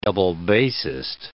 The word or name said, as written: double bassist